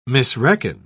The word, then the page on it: misreckon: British English